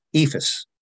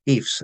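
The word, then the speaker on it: EIFS: A case in point is in our home state of New Jersey and the surrounding states, EFIS, or EFS, as some people prefer to pronounce it,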